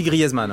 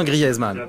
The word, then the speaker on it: Griezmann: On dit Griezmann, hein. Je le dis bien Griezmann. Griezmann. Comme merguez, tu vois, grillaise.